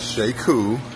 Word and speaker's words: Sheku: And my name is Sheku. It's pronounced like she cool, kind of like she's cool.